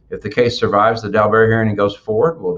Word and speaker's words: Daubert: We have to have, you know, what's known as a Daubert hearing, or some people may say, pronounce it a different way other than me, but that's how we say it in Texas, Daubert hearing, to get all the experts on each side to testify.